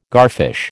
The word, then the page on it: garfish: American English